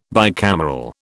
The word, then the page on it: bicameral: British English